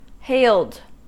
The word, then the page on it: hailed: American English